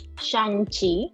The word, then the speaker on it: Shang-Chi: In August, I believe, Awkwafina had a interview for her new movie, Shang-Chi. Is that how you pronounce it? – Shang-Chi.